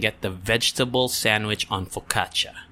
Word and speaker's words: focaccia: Focaccia. Is that how you say it? Focaccia. Pronounce. Let's ask how to pronounce it. How to pronounce focaccia? F-O-C-C. Focaccia. Okay, YouTube says focaccia.